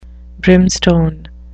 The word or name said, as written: brimstone